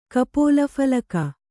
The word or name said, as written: ಕಪೋಲಫಲಕ